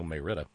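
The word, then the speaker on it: Marietta: That's how you say it, you know, Mayretta.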